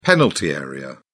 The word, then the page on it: penalty area: British English